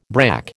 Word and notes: brack: American English

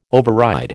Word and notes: override: American English

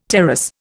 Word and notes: tarras: Indian

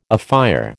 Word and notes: afire: American English

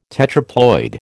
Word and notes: tetraploid: American English

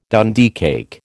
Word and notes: Dundee cake: American English

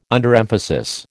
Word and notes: Underemphasis: American English

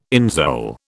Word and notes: insole: British English

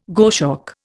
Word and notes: goshawk: Indian

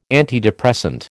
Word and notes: antidepressant: American English

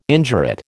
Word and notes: indurate: American English

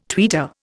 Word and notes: tweeter: Indian

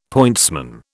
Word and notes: pointsman: British English

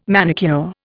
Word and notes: manicure: Indian

